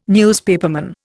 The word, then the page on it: newspaperman: Indian